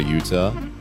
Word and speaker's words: Utah: Stay safe out there, Utah.